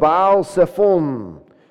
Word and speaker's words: Baal Sephon: in front of Baal Sephon."